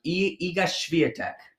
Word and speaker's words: Iga Świątek: is Iga Świątek.